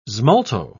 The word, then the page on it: smalto: British English